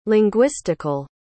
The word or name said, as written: linguistical